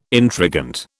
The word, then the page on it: intrigant: British English